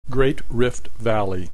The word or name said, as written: Great Rift Valley